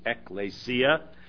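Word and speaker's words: ekklesia: Ecclesia, and apparently the proper way to pronounce this is ecclesia, which is an assembly or a church.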